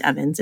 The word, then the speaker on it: Ewens: It looks like Ewens. It's Stephanie, E-W-E-N-S. But it's pronounced Evans. It's weird, I know.